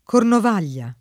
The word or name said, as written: Cornovaglia